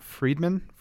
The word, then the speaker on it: Friedman: GitHub's new CEO will be Nat Friedman, F-R-I-E-D-M-A-N.